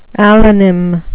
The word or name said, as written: allonym